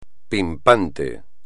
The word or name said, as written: pimpante